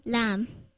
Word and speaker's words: lamb: Well, I know a silent B word. – What's that? – Lamb. L-A-M-B. – Oh, yeah. That's right. – Cam. C-A-M-B.